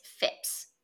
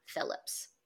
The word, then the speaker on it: Phipps: The first wealthiest man who will come into play later is a man named Lawrence Phipps Sr. That's not a mispronunciation. It's Phipps, not Phillips.